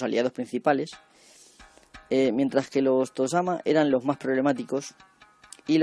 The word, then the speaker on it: tozama: En general, aunque la gente tiende a pensar lo contrario, los Tozama, o Tozama, se pronuncia en japonés, eran los que tenían dominios mayores casi siempre.